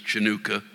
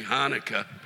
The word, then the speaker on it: Hanukkah: Actually, to say it properly, you have to say some people have actually choked to death on their phlegm trying to say Hanukkah,